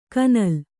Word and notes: ಕನಲ್: kanal

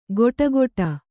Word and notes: ಗೊಟಗೊಟ: kannada

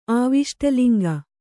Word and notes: ಆವಿಷ್ಟಲಿಂಗ: kannada

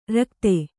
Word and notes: ರಿಕ್ಥ: Kannada

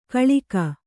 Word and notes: ಕೊಳಕು: Kannada

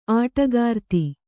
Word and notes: ಆಟಕಾರ್ತಿ: āṭakārti

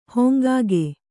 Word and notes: ಹಂಗಿಗ: hangiga

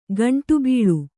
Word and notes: ಗಂಟುಬೀಳು: gaṇṭu bīḷu

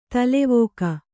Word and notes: ತಲೆವಾಗು: talevāgu

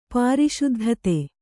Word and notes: ಪ್ರಸಿದ್ಧತೆ: prasiddhate

